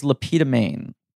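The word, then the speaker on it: Le Pétomane: I believe his character, I believe that the man's name was Lapita Maine. I might be mispronouncing. – Yeah, no, you're right.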